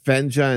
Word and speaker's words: Fenja: Is it Fenha and Menha or Fenja and Menja? – Fenja and Menja.